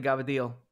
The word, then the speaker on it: cavatelli: So it would, broccoli gabbadil is what we'd say. It's, long story short, that's how we would pronounce cavatelli.